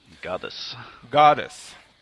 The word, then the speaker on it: Godus: som heter Godus, eller jag vet inte hur man uttalar det. Goddess, precis.